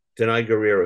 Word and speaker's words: Danai Gurira: Danai Gurira, who plays Richard.